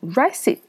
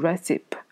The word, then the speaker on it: recipe: 这个单词很多学生会读成 recipe，或者是 recipe，但是这个词呢，嗯，它和一般的、一般的这个 e 结尾单词呢是都不发音的，不不会把它这个发出来。但这个词比较突特殊，它会把这个 e 这个词发出来，所以说这个词应该读成 recipe，recipe，所以说不能读成 recipe，而是 recipe。